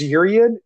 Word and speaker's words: Urien: So urine is it? Uh, I always say urine, like like piss, but mm-hmm. – I think it's I think it's urian. Urian is the way you pronounce it.